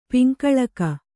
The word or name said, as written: ಪಿಂಕಳಕ